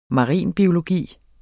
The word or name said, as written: marinbiologi